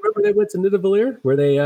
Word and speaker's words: Nidavellir: It's all part of Nidavellir. Am I saying it right? Nidavellir? – Nidavellir.